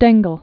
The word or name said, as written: Stengel